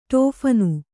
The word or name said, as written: ಟೋಫನ್